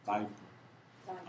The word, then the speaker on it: diaper: 다이 퍼. 아이가 첫 번째 강세가 있으면 되게 그 발음이 아이로 나와요.